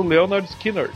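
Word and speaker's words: Lynyrd Skynyrd: Então é Linird Skinnerd que se escreve. Mas pronuncia, graças à ajuda dos do, dos músicos que esclareceram a, a origem do nome, tu fala como se estivesse dizendo Leonard Skinner. Então fica Leonard Skinner.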